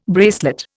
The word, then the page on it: bracelet: Indian